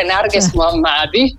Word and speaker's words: Narges Mohammadi: Il fatto di poter parlare la pronuncia è una pronuncia iraniana, quindi secondo me né tu né io la faremo mai giusta, però è Narges Mohammadi.